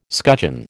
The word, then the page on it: scutcheon: American English